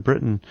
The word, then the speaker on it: Britten: He was formerly the head opera critic at the BBC, but he was in town covering the American preview of Gloriana, Benjamin Britten, or Brighton, I think it's pronounced, production.